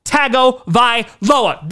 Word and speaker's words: Tagovailoa: and that is Tua Tagovailoa.